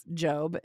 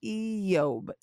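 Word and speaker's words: Eyob (איוב): His name is pronounced Eyob. It's not really pronounced Job in Hebrew. Eyob. You can say that for yourself 50 times if you want, or you can just call him Job.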